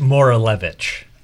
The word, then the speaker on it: Moralevich: It is Pete Moralevich. I tell people it sounds like Maury Povich, except not Maury Povich.